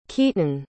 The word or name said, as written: Keaton